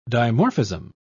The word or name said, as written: dimorphism